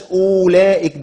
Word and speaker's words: أولئك: بس خدي بالك بلاش اولئك دول لان او الواو هنا غير منطوقه اصلا، هي مرسومه فقط عليها سفر المستدير، معناها انها لا تنطق في الوصل ولا في الوقف حتى يعني.